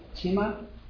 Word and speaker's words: Cima: And 6 miles north, probably I'm going to butcher this name, C-I-M-A, Chima? Chima, California.